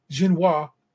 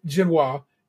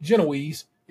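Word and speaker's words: génoise: The orchata and espresso ice creams are separated by a layer of cake wrapped in a vanilla genoise. I like that the Americans pronounce it genoise, and like the British who call it Genoese, it is genoise, people.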